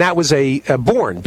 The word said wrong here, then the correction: Boerne: B-O-E-R-N-E, Born. – It was Bernie, yeah. – Bernie, yeah. – Correct. – No, that's right. They pronounced that horse, that's Bernie.